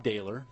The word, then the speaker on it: Dehler: My name is Bob Dehler. Last name is spelled D-E-H-L-E-R.